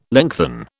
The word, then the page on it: lengthen: British English